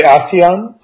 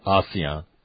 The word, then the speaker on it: ASEAN: How do you correctly pronounce our partnership in the Pacific? – I would usually say ASEAN. – ASEAN.